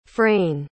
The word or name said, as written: frayne